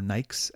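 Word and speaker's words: Nike: London these days is all about their Nikes, or Nikes as it's pronounced there.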